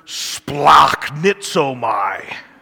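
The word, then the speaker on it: Splachnitzomai (σπλαγχνίζομαι): Now, the Greek word that's translated in our English as compassion is this word splachnitzomai. It's kind of a weird sounding phrase, but you got to get that guttural sound in there.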